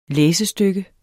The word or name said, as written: læsestykke